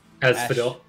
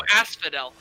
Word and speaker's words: Asphodel: destination of Aspold. How do you pronounce that? – Asphodel. – Ash- Asphodel. Oh, Asphodel. Yeah.